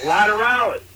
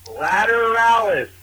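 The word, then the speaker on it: Lateralus: Chris, you fucking butchered that name. It's Lateralis. Lateralis. Like Tool. All right, bye. – I used an L at least, I think. I think I said Lazarus or something.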